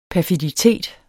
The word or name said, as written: perfiditet